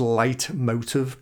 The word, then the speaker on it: leitmotiv: So it's a German phrase. It's pronounced leitmotiv, but it's not spelled it.